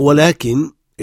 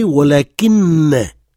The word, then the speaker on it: ولكن: Tout dépend de comment on prononce ولكن. On peut dire ولكن, et dans ce cas, après, on revient à un cas sujet. Et on peut prononcer ولكن, et dans ce cas, le sujet qui suit prend la voyelle E, comme si c'était le cas direct.